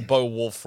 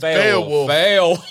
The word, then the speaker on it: Beowulf: I'm also not pronouncing Beowulf right. – It's Beowulf.